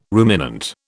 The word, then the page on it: ruminant: British English